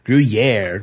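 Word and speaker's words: Gruyère: Gruyere, which I pronounce Gruyere, is actually Gruyere. Gruyere rhymes with air, he says.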